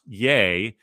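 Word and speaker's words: Ye: It is pronounced. It's pronounced yay.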